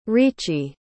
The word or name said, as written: riichi